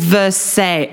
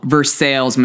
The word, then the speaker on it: Versailles: It's spelt Versailles, like how we would say the Palace of Versailles. – How everybody would say the Palace of Versailles. – Apart from the Kentuckians who would say Versailles.